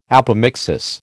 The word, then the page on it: apomixis: American English